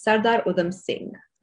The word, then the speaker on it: Sardar Udham Singh: And lastly, correct me if I'm saying this wrong, it's Sardar Udham Singh.